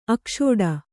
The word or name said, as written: ಆಕ್ಷೋಡ